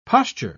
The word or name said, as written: posture